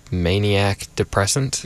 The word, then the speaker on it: Maniac Depressant: And our first song was Gonja Sufi with, I think we settled on, Maniac Depressant. – Yep. – Because that's how it's spelled.